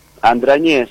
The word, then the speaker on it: Andragnés: Andragnés, es vasco francés, se pronuncia sin la G.